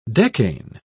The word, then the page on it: decane: British English